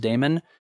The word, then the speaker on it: daemon: It is pronounced daemon.